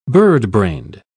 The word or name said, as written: birdbrained